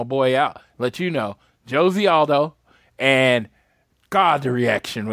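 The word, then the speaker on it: José: Yeah, Jose Aldo. – Dude. No, and just to clarify, it's Jozy. I don't know why. I don't know why, but they pronounce the J in Brazil, so it's Jozy Aldo.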